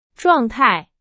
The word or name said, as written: 状态